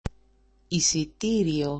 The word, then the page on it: isitirio: isiti-rio